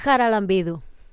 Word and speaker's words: Charalambidou: Charalambidou.